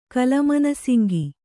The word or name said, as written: ಕಲಮನಸಿಂಗಿ